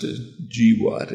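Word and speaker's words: GWOT: It's pronounced GWAT, if you want to know. Don't ask me why.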